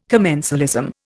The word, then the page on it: commensalism: Indian